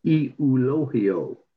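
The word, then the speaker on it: Euglio: His last name was De La Cruz, which, you know, fairly common. But his first name was E-U-G-L-I-O. E-U-G-L-I-O. And the West Michigan radio play-by-play guy said, it's pronounced E-U-L-O-H-I-O. Okay. E-U-L-O-H-I-O. Okay, got it.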